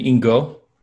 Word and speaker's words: Ingo: so most people both in Iceland and here call me Ingo, which is like bingo with no B, very easy.